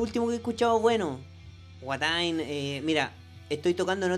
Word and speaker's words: Watain: Watain, eh, W-A-T-I. – Watain. Sí, Watain, sí. No como Wata con G.